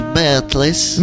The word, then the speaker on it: Beatles: Qual è la pronuncia esatta dei Beatles? – Beatles. – Non si dico Rolling Stones. – Ma no, ma non credo. Non all'inizio credo. Si chiamavano Pooh, poi hanno cambiato. L'immagine dei Beatles attraversano la strada è la celeberrima copertina dell'album Abbey Road.